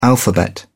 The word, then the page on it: alphabet: British English